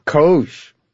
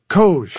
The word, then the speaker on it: COGE: How do you pronounce the acronym for this commission? – COGE. – COGE. – COGE. It is exactly what you think it is, Steve. And I know what's in the back of your mind when you ask that question. – Go on. What is in the back of my mind? – It does rhyme with Doge.